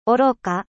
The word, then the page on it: stupide: French